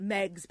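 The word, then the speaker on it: Meigs: And Dr. Miggs, on the other side, we're pronouncing it Miggs, but it's M-E-I-G-S, right? – Yes, I think that they pronounced it Meggs back Meggs.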